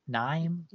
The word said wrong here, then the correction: Niamh: Niamh Kavanagh.